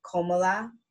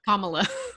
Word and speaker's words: Kamala: Komala. – Kamala.